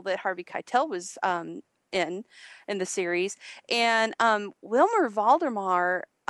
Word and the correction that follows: Valderrama: Valderrama. I can never say that right.